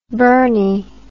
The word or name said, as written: Burnie